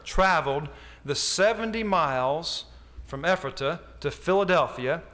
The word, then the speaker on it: Ephrata: This Peter Miller was a Baptist pastor, and he lived in Pennsylvania, a town called Ephrata, if I said that right. Ephrata, had it right the first time.